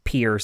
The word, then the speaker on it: Pierre: A decade ago, I happened to live in the great metropolis that is Pierre, South Dakota. And yes, that is how you pronounce it.